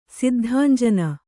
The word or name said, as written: ಸಿದ್ಧಾಂಜನ